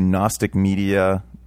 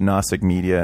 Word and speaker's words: Gnostic Media: So if you have the same level of intelligence as me and you think it's spelled with an N first, you're incorrect. It's Gnostic space, then media, M-E-D-I-A.